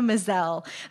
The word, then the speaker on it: Maazel: It's A Little More Human by Fiona Mazel. Rhymes with gazelle, as you were telling me before the show. – Yes, that's pretty much how she introduced herself to me. – That's great. It's spelled M-A-A-Z-E-L if you go looking for this online.